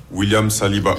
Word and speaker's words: William Saliba: William Saliba.